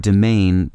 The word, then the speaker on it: demesne: Why isn't the word demesne pronounced the way it's spelled? Our word actually began as demayn, D E M A Y N, or D E M E Y N, in the 14th century when it was borrowed from Anglo French property law.